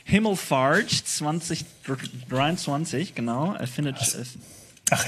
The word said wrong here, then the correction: Himmelfahrt: Ja, ich habe es ein bisschen Englisch ausgesprochen und habe ich gemerkt, oh. – Fahrt ist ja was anderes. Fahrt in Englisch, das, nee. Himmelfahrt, Deutsch.